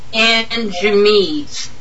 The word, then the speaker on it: Anjemes: It's A-N-J. How do you say that? A-N-J-E-M-E-S? – Anjamees.